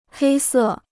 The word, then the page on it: 黑色: hēi sè